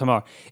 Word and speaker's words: Hamar: Now, is it Hamar herders? Is that how you say it? – They're the Hamar tribe. Yeah, it is Hamar.